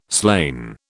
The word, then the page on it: slain: British English